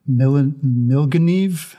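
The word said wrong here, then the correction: Milngavie: Milgeneve and Fort William. What is it? Correct me, Rab. – We've definitely Scottished you here. It's actually pronounced Mulgai. – Mulgai. It looks nothing like that.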